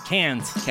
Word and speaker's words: Cannes: No, like Cannes festival. I was told. I was pronouncing it that way. And apparently that is embarrassing. – Con. James Con. – How do you say Cannes? – Cannes.